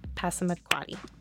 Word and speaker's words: Passamaquoddy: So the place you clap the most often, the qua syllable, is the primary stress, passamaquoddy. The place where you clap the next most often, the pa, is the secondary stress, passamaquoddy.